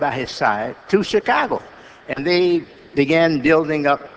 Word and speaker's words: Chicago: Daley, once I was listening to him on television talking about the great city, our great city of Chicago, and he said, you don't pronounce it Chicago, it's chi, like chick, you know, or like chicken, Chicago.